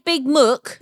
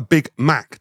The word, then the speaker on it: Big Mac: So you say Big Muck? – No. Because, No. A Big Mac is spelt M-A-C. That's called a Big Mac. That's how it's... You're saying Mac, M-A-C, Donald's.